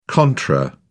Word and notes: contra: British English

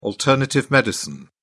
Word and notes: alternative medicine: British English

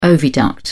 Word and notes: oviduct: British English

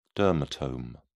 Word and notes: dermatome: British English

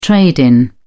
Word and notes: trade-in: British English

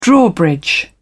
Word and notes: drawbridge: British English

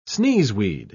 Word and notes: sneezeweed: British English

